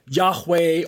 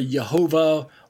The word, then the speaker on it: Yahweh: otherwise pronounced Yahweh or Jehovah or Jehovah, or some people say Yahuwah, things like that. So I myself favor Yahweh.